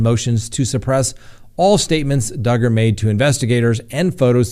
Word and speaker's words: Duggar: Next on the docket, Josh Duggar, rhymes with hugger.